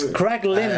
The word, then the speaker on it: Kraglin: So, Kraglist at the beginning... – No, it's Kraglin.